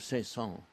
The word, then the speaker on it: Saint-Saëns: Before you came, there was a rather important conversation about the pronunciation of Saint-Saëns. There's an S there.